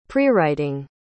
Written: prewriting

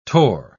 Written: tore

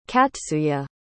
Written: katsuye

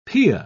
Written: pia

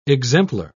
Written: exemplar

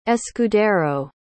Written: escudero

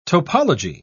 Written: topology

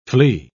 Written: flee

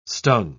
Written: stung

